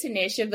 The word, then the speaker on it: Tanisha: No, I say it Tanisha, but it's perfectly fine.